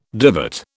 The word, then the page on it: divot: British English